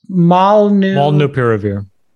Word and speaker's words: Molnupiravir: It's Molnupiravir.